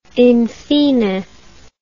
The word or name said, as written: infine